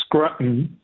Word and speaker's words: Scruton: I hope I'm saying that right. – That's right. – Yeah. No, I think it's Scruton, isn't it? – Scruton. – I think so. – Maybe. – His last name is S-C-R-U-T-O-N. S-C-R-U-T-O-N.